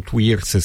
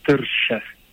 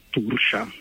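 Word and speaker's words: Tuirse: Allora, gaelico irlandese è come giustamente dici, la pronuncia corretta, senza pretendere peraltro di averla nemmeno io, però sarebbe Tersche. – Ah, ok, Tersche, ok. – Tersche o Tursha, se vogliamo. Latinizzarlo un pochino, sì, perché appunto quello che si scrive non è decisamente quello che si legge in gaelico.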